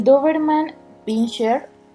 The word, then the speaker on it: Doberman Pinscher: Y bueno, fíjense que, que, uno de, bueno, el primer dato curioso que, encontramos por aquí es que el Doberman Pinscher, no sé si así se pronuncia. – Correcto.